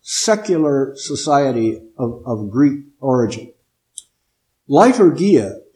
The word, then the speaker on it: leiturgia: And the word is, let's see, English pronunciation is liturgia. Liturgia. Now, I'll spell it with English letters so you can write it down. It would be spelled L-E-I-T-U-R-G-I-A, liturgia.